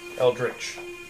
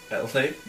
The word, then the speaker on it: Eldritch: Eldritch. – See, I try to say it right. I'm still saying it wrong. – Eldritch Blast. – Eldic Blast.